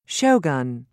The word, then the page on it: shogun: British English